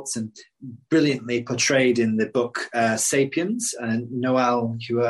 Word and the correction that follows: Yuval Harari: I can never pronounce his name, Yuval Harari, talks about two different realities.